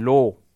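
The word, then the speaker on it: Lo (לא): so wird mehr oder weniger ausgesprochen, Lo.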